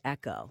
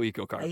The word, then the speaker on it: EcoCar: Am I describing, is EcoCar the correct pronunciation, or is it Echo? – Yep, we go EcoCar. – Eco, okay, good.